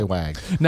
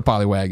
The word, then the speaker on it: Poliwag: Poliwag. Now, if that I said wog. – Yeah, it's, you said it wrong.